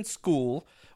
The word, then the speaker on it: Skule: All right, the second prospect that we're going to be talking about today is Justin School, which he's got the coolest spelling ever, S-K-U-L-E, and it's pronounced school, like kids go to school.